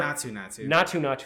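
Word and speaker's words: Naatu Naatu: The opening interview / You Cannot Start Without Me in Tar, Nanu Nanu from RRR. – Natu Natu from RRR.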